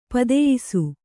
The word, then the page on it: ಪದಯಿಸು: padayisu